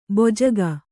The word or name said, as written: ಭೋಜಕ